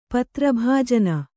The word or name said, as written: ಪತ್ರಭಾಜನ